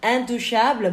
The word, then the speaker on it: Intouchables: Intouchable, bon, ça se prononce intouchable, tapi tulisannya intouchables.